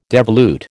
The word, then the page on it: devolute: American English